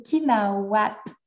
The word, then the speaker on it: Kina8at: Puis là, je dis C'est parce que le 8, pour ceux qui ne savent pas, Kinawat, ça s'écrit avec un 8, donc K-I-N-A 8-A-T mais le 8 est vraiment un W dans l'alphabet algonquin.